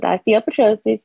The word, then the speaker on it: osteopetrosis: And at 2, I was diagnosed with osteopetrosis. And you said it right, by the way. I know it's kind of hard, but no, that was right.